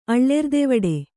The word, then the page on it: ಅಳ್ಳೆರ್ದೆವಡೆ: kannada